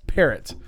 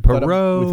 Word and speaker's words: Parrott: We Perot. – We thought it was Perot. – Perot. – But we came up with Parrott.